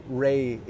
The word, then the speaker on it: Ray: So my name, so Ray in Spanish is king, right? Mm-hmm. So I spell it R-A-Y.